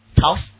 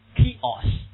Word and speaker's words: chaos: 这个怎么读啊？吵死 no。不是吵死啊，当然吵死挺好读，right？吵死了，吵死了，right？那、 no, 怎么读这个词？chaos，哎，很难受啊。